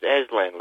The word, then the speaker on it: Aslan: It's Aslan, like the lion. – Can you spell that? – It's the A-S-L-A-N.